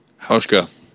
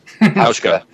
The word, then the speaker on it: Hauschka: How do you say that? – Hauschka. Hauschka.